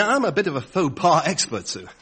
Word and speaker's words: faux pas: what's this say? Fox pas? – Faux pas. It's pronounced faux pas. It's French.